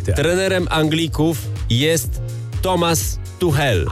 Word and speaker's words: Tuchel: No ja sobie pomyślałem, że byli na autostradzie do półfinału, do finału, ale wylądowali w piekle, więc pomyślałem sobie Highway Tuchel. – Albo Tuchel. – A ty wtedy powiedziałeś, że on się wymawia Tuchel.